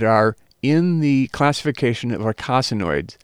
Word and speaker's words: eicosanoids: The pronunciation is eicosanoids, and I'll spell it for you.